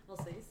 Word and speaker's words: Alsace: How do you say it? Alsace. – Yeah.